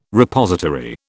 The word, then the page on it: Repository: British English